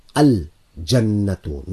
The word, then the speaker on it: Al Jannatu: Al Jannatu. Notice the lam is being pronounced Al Jannatu.